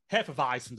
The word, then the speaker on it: Hefeweizen: Trixie's Alibi Hefeweizen. Did I pronounce that right? – Perfect. – Oh, okay. Accidents happen. – You even got the V for the W.